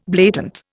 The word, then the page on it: blatant: Indian